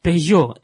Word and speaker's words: Peugeot: Peugeot. According to the French English language rules, if the T is at the end of a word, you don't pronounce it.